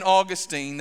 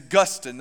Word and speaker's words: Augustine: So Augustine, for instance, now Augustine, that is St. Augustine, that's what it is when it's Florida, but when we're talking about this 4th century theologian and pastor, his name is Augustine, that's the way we pronounce it.